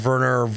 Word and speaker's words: Werner: So it's like, it's a W, but it's pronounced like a V, right? So it's like Werner Herzog.